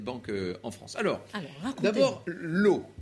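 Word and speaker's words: Law: Bon, ben, L'eau, à la cour, on l'appelle l'as.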